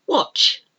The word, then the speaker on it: watch: But it's got a silent T right in the middle. W-A-T-C-H. Watch. Watch. You don't say watch. You don't say the T. Watch. It actually is difficult to say anyway. So it's watch.